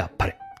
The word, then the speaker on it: 天晴: ここにも表記してありますように、あっぱれは天に晴れると書いてあっぱれと読みます。